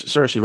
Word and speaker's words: Saoirse: Saoirse, I hope I'm saying that right. – Yes, Saoirse Ronan.